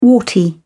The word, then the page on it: warty: British English